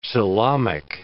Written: coelomic

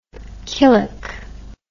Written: killock

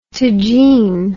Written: tagine

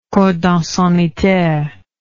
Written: cordon sanitaire

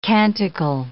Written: canticle

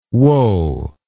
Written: wo

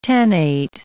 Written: tannate

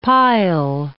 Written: pile

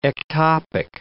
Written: ectopic

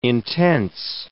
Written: intense

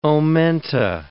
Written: omenta

